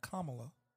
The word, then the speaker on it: Kamala: Kamala. Let me say her name right. Kamala.